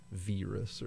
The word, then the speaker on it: Viras: It's easy to blur that into virus, but it's definitely a long I sound as the vowel instead of a Viras or something.